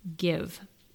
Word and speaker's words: GXVE: And the collection is pronounced Give. So I guess the X is pronounced as an I.